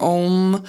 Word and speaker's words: Om: He was talking about Om and how it originates in the back of the throat, like ah, because it's spelled A-U-M and people kind of argue about how it's pronounced, either Aum or Om.